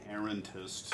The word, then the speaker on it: errantist: So, We talked about, so the sort of the benefits of being an errantist, did I say that right? – Yes.